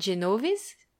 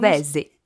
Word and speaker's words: Genovese: Il vous le faut 45 feuilles de basilic Genovese. C'est comme ça qu'on prononce. – Genovese.